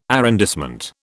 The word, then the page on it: arrondissement: British English